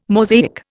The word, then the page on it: Mosaic: Indian